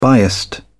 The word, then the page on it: biased: British English